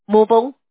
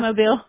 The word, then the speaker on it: Mobile: My best friend, she was born in, I always say it wrong, Mobile, Alabama. – Mobile.